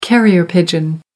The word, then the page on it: carrier pigeon: American English